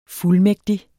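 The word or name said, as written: fuldmægtig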